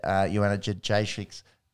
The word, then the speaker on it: Joanna Jędrzejczyk: Did have that win, which essentially ended Joanna Jędrzejczyk's career.